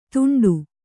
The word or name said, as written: ದುಂಡು